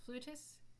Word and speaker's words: flutist: I believe it is flutist because we play the flute, especially in America. And maybe many European countries, it's pronounced flout, and that's why where the word flautist kind of came from. So, you know, it's both are fine. Both are accurate.